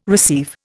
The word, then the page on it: receive: Indian